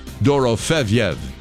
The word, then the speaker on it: Dorofeev: Dorofeev is how you would properly pronounce his last name, Bob.